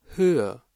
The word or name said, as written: Höhe